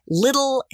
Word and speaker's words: Lidl: at Little, that's spelled L-I-D-L, at Wegmans, Peapod, and Costco Warehouse from Massachusetts Massachusetts down to Washington, D.C.